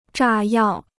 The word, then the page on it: 炸药: zhà yào